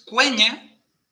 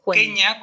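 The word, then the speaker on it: Quenya: La Q siempre se pronuncia con U. Por eso es cueña y no queña, como pronunciaríamos a la española.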